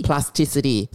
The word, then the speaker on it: plasticity: Plasticine, right? Plasticity. – Plasticity. – Four syllables.